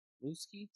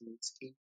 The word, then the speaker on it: Mooski: So, rapper Mootski, is that how you say his name? – I'm not going to lie, I have no idea who this guy was, but I think it's Mootski.